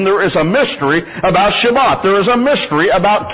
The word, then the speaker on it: Shabbat: But do you realize the emphasis in which God put upon the Sabbath, the Shabbat, as it's pronounced in Hebrew, and not polluting the Shabbat, which is from sundown on Friday till sundown on Saturday?